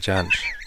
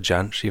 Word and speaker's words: Jansch: Well, when me and Bert used to hang around in the Edinburgh folk clubs, he pronounced it Jansch. – So that was almost a Billy Connolly. – It was almost a Billy Connolly. No, he pronounced it Jansch. And so I've always said Jansch, even though it's one of those things.